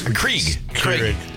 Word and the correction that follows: Keurig: Keurig. Yeah. You don't even know how to say it.